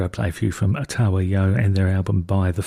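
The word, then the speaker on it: Otava Yo: There's another more Russian way of spelling it, but apparently it's pronounced Otawa Yo.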